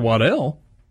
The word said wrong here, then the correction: Waddle: Ooh, nice pronunciation there. – Did I get it wrong? – Isn't it Waddle? – It is Waddle, isn't it?